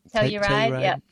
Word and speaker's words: Telluride: Well, have fun in Telluride. Is that how you say it? – Telluride. Yep.